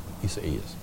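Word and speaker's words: Isaías: Remember the Spanish pronunciation of Isaiah, Isaias.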